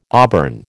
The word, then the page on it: auburn: American English